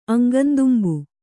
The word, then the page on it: ಅಂಗಂದುಂಬು: Kannada